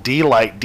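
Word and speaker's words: Deee-Lite: It's, it was definitely a one-hit wonder by a group called Deee Lite. D-E-E-E. It has three E's. – Deee Lite. – Deee Lite. – Because you can't spell it just delight. That would be wrong.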